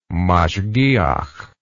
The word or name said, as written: mashgiah